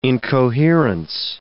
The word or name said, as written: incoherence